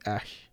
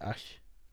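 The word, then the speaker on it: Æ: I think X is just X, And then AE is supposed to be Ash. That's how you're supposed to pronounce it, Ash. I think Ash.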